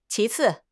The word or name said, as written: qi2 ci4